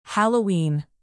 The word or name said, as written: halloween